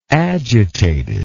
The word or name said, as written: agitated